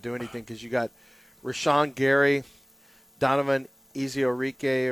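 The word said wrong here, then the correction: Ezeiruaku: or whatever. – Ezeraku. – Yeah, Ezeraku.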